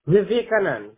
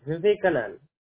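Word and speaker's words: Vivek Anand: A-N-A-N-D. – Wow. I had it totally wrong. How do you say it? – Yeah. Vivekanan. Vivekanan. – Canon. Okay. V-I-V-I-E-K-A-N-A-N-D. – Yeah. Spell it back for me. – V-I-V-I.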